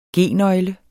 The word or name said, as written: G-nøgle